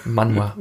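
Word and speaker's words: Manhwa: Manma. Manma, genau. Manma. Ich dachte, irgendwas mit der ersten Silbe stimmt dabei nicht, aber es ist die letzte Silbe.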